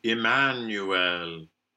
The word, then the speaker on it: Emmanuel: Now there in connected speech, that proper name, Emmanuel, usually with schwa. But here we've talked about in Messiah a tradition of some of these weak final syllables being pronounced e, and that isn't wrong. There is actually good literature that supports this. So we can opt for Emmanuel.